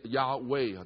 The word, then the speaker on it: Yahweh: 事实上那个名词比较正确的发音应该是 Yahweh，但是他们不敢发这个音。